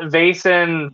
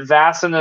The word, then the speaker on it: Vaesen: And so uh, you heard you had people saying that Vason, Vason, you know, um, mm. all sorts of stuff. But Vaesen is the correct pronunciation, and that is how mm.